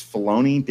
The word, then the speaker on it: Filoni: It's Filoni.